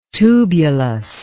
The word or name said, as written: tubulous